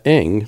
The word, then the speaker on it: Ng: A fashion designer and TV personality, also known for her amateur radio articles and videos for Make, that's spelled M-A-K-E, magazine, has become a member of the American Radio Relay League's Public Relations Committee. Ayanna Ng, spelled E-N-G,